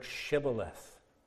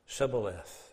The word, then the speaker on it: Shibboleth: They couldn't pronounce the word Shibboleth the way they pronounced it was Sibboleth. They couldn't pronounce Shibboleth, Sibboleth.